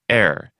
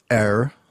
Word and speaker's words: err: Take, for example, err, E-R-R. – If you look up the word in Webster's third, you get at least five pronunciations. They are air, air, like the air we breathe. Er, the very old-fashioned one. Air, which I don't recall hearing myself. And the more British, air, without even an R. So if you want to know how to say this word, E-R-R, you're in for some very hard work when you open Webster's third.